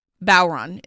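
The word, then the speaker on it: Bodhrán: This one is like a Bowron drum. And it's Bodhran. It's Irish. – Mm-hmm. – So, of course, the pronunciation is completely fucked. – Yeah. – Bowron is how you pronounce that.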